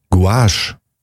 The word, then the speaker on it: Gouache: G-U-A-C-H-E. – G-O-U-A-C-H-E. – Guache. – Guache. – Guache. – So spricht man es aus.